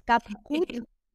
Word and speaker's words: CapCut: Bueno, yo lo pronuncio en inglés, que la gente le molesta, es CapCut.